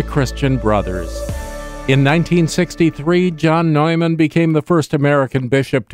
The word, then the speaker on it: Neumann: And today is the memorial of St. John Neumann, or Neumann in his native pronunciation.